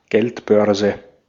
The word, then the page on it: purse: /ˈpɜː(ɹ)s/; [ˈpɜː(ɹ)s]